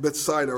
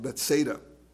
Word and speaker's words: Bethsaida: And in verse 20, he issues a warning saying, woe to you, Chorazin and Bethsaida, or actually it should be pronounced Bethsaida. Woe to you, meaning pity on you.